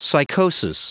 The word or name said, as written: psychosis